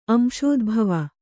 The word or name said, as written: ಅಂಶೋದ್ಭವ